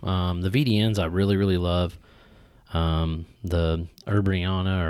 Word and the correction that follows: Amburana: or Ambriana, however you want to pronounce Am- it. Amburana. Ambriana, yeah.